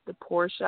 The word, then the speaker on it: Daporscha: My name is The Portia.